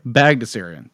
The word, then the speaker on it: Bagdasarian: How do you pronounce the guy who created... – Magnusarian is my best estimation. – Magnusarian? – Bagdasarian. – Thank you.